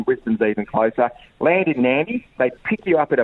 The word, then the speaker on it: Nadi: So you fly out of Melbourne to Nandi, which is really weird because it's spelt N-A-D-I, but it's pronounced Nandi.